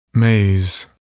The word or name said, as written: maize